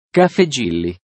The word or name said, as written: caffe gilli